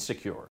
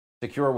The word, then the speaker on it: Sekur: That's why you need Secure. It's spelled S-E-K-U-R.